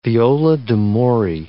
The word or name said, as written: viola d'amore